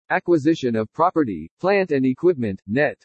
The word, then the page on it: ACQUISITION OF PROPERTY PLANT AND EQUIPMENT NET: english